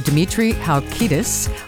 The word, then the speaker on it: Dimitri Halkidis: And we are happy to welcome as our guest on this episode, Dimitri Halkidis.